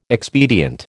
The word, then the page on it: expedient: American English